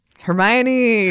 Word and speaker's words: Hermione: Hermione. – Hermione Granger.